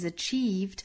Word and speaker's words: achieve: 后面再停顿一下，同样 and 弱读成 n， and happiness is achieved。我们注意 achieve 这是一个长音 e，我们刚才讲到这个 greatest 最后一个元音是一个短音 a，那么这里面的 achieve 它是一个紧张的长音 e， achieved through hard work 两个卷舌，一个是上下开口度 hard，一个是左右的 work。